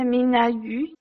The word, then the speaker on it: Minna Yu: En fait, mon nom et mon prénom, si je prononce euh, en mandarin, c'est ça, Yu Mingna. Yu, c'est le nom de famille, Mingna, c'est le prénom avec un ton un peu différent que euh, français.